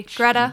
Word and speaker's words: Greta: My name is Greta Eliason, a.k.a. G-Baby. A.k.a. Greta G-Baby. Like feta. Not Gret. Not Greet.